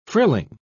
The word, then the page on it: frilling: British English